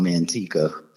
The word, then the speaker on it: Manteca: This area that nobody knows about, called Manteca. – Can you spell that for me? – Chill with. Yeah, M-A-N-T-E-C-A.